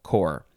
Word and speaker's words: corps: And I know this word confuses a lot of people because there's two letters at the end that are silent. Why would they do that? C-O-R-P-S, you do not pronounce the P or the S. Unless it's plural, and then you pronounce the S.